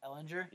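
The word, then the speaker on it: Ehlinger: Ellinger? – Yeah. – Okay. Sam Ellinger.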